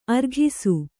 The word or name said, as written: ಆರೊಗಿಸು